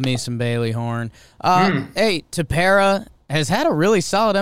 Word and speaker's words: Tepera: They also got Ryan Tepera. It was a rental. Is that how you say it? Tepera? – Yeah.